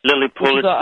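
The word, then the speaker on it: Lilly Pulitzer: Am I saying this right? Pulitzer. – Lily Pulitzer. Yes, absolutely.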